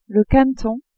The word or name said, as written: caneton